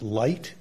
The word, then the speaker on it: Leite: This court in, I think it's pronounced Light, L-E-I-T-E, and a failure to warn asbestos case, talked about that.